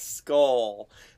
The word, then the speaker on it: Schull: I'm like a 30-minute walk to a town called Skull that is spelled S-C-H-U-L-L.